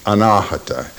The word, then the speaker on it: Anahata: As I say, its name is Anahata, A-N-A-H-A-T-A, the second A being a long A.